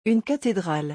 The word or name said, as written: Une cathedrale